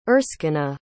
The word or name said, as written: erskina